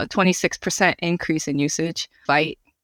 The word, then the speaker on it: Vite: Vite is how everyone I've talked to, yeah, on the team and everything.